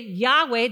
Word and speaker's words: Yahweh: But we pronounce it Yahweh, not that it's less reverence, but that is an actual proper name.